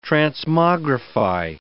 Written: transmogrify

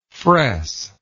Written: fresser

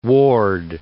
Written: Worde

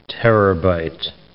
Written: terabyte

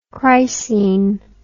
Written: chrysene

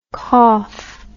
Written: coff

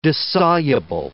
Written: dissoluble

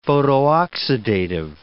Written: photooxidative

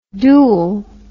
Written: dool

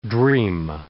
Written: dream

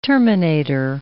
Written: terminator

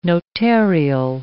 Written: notarial

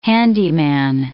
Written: handyman

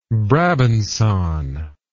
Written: brabancon

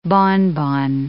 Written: bonbon

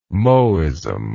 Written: Mohism

